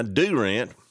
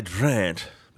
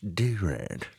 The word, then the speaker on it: Durant: Durant, 62. Don't make fun of the way I say that. That's how you say it. Some of you more educated might say Durant, but it's Durant, actually.